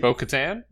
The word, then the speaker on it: Bo-Katan: I think the inclusion of lore from the Clone Wars, and that's where I'm not going to say Bo-Katan, played Bo-Katan.